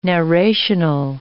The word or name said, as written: narrational